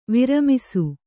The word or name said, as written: ವಿರಮಿಸು